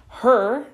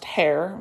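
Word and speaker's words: Herr: Her, not pronounced hair, many people mispronounce this. Her is my husband's last name, Evan Her. And like Her's Chips, if you're familiar with the Pennsylvania area, Her's Chips, same deal.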